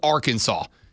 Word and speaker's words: Arkansas: In 1881, the state's General Assembly passed Resolution 1-4-105, declaring that the state's name should be spelled Arkansas, but pronounced Arkansas. So it is actually state legislation that the correct pronunciation is Arkansas.